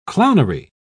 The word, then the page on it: clownery: British English